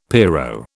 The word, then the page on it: pierrot: British English